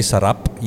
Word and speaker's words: sarap: If you're going to say sarap, you need to kind of roll your R's.